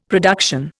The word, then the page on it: production: Indian